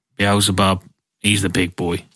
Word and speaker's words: Beelzebub: No, how do you pronounce that? I don't want to get possessed. – Beelzebub. – Beelzebub.